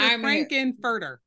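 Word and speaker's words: Frank-N-Furter: I'm Frankfurter. Wait, what's his name? – That's it. How you say his name? That's it. Dr. Frankenfurter.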